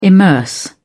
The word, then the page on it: immerse: British English